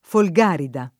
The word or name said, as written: Folgarida